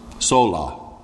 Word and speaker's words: sola: And so the first thing is the five solos, right? Am I saying that right? Solos? Sola. Sola. The five solas.